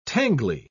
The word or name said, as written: tangly